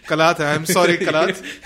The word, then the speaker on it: Kalat: ये स्पेलिंग बताएं क्या है कलत की. क्या KALAT. – हाँ ये कलत नहीं है. – हाँ ये कलात है.